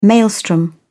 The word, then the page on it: maelstrom: British English